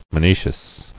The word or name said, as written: Monoecism